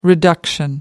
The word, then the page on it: reduction: American English